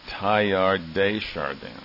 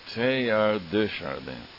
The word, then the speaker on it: Teilhard de Chardin: But that word, Teilhard de Chardin, is not Teilhard de Chardin. Okay, it's Teilhard de Chardin.